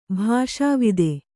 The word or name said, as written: ಭಾಷಾವಿದೆ